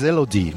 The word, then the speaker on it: Elodie: Eloide is up next, I believe. – After is Elodie.